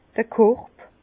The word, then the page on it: d'r Korb: Bas-Rhin; Herrlisheim